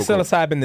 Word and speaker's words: psilocybin: And psilocybin, the P is silent.